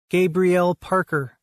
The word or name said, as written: Gabriel Parler